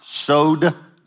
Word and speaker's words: sozo: It's a Greek word. It's pronounced sozo.